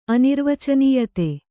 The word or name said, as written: ಅನಿರ್ವಚನೀಯತೆ